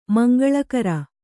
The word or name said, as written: ಮಂಗಳಕರ